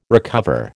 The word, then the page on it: recover: American English